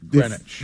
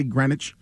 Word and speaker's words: Greenwich: It is pronounced but if you, Greenwich. If you're not from around here, somebody drove past that sign, they wouldn't say Greenwich.